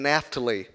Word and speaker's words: Naphtali: Zebulun and Naphtali.